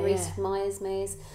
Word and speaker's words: Rhys-Meyers: Yeah, Rhys-Meyers.